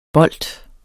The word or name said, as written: bolt